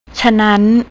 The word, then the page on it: chanan: thai